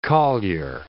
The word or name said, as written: collier